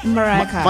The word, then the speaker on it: Mariah Carey: Eso, ahorita viene Mariah Carey, y en esta, ¿lo dije bien? – Mariah Carey.